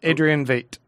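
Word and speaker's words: Adrian Veidt: Adrian Vate. – How do you know that? – That's how they pronounce it in the movie too. Adrian Vate.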